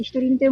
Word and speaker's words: 独り身: もう独身と書いて独り身と読むんですけど、独り身になりました。